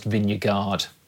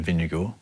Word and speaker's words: Vingegaard: Note that that was the Danish pronunciation of Vingegaard as opposed to the anglicised Vingegaard.